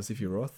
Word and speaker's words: Sephiroth: Die haben ja Sifi Ross gesagt. Aber Sifi Roth, sagen Engländer. Ich weiß gar nicht, wie es im Japanischen ist, wie es genau ausgesprochen wird. Sephiros. Ja, sagen auch eigentlich Sephiros. – Ja. – Also mit S am Ende.